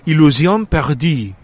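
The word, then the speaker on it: Illusions perdues: Para mí, quizá la mayor revelación en el sentido de que no esperaba demasiado de ella y ha sido Illusion Perdue. ¿Cómo lo he pronunciado? Très bien.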